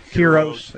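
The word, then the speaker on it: Quirós: The Quiros? Am I saying it right? – Yeah. Quiros.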